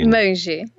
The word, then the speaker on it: Monge: Monge is how it sounds in Portuguese.